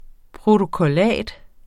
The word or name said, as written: protokollat